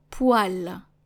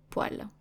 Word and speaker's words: poêle: It is spelled P-O-E, accent circonflexe, L-E. Donc une poêle. Une poêle.